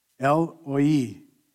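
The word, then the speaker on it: El Roi: It's spelled R-O-I, El-Roi, which is what I usually say, but the R is actually silent. El-Roi is how you say it.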